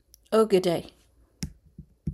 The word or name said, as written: Ogedei